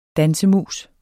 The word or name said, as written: dansemus